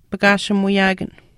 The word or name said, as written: pakaashimuuyaakan